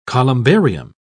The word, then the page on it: columbarium: British English